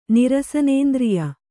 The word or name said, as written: ನಿರಸನೇಂದ್ರಿಯ